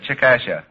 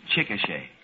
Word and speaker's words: Chickasha: C-H-I-C-K-A-S-H-A. I said Chickasha. – Oh, Mr. McNeil. – That is not right, I've no, taken. No, no. But the name is pronounced Chickasha.